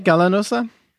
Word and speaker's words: Gallanosa: Well, I grew up closer to Mexico, so with the double L, all the teachers said Gallanosa. – Okay. – So I was like, sure, that can work too.